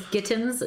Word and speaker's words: Gittins: Hi, my name is Gittins. It rhymes with mittens.